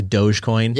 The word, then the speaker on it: Dogecoin: Dogecoin.